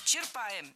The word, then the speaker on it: черпаем: Черпаем, неверно. Правильно, черпаем. Здесь ударение тоже должно падать на корень слова.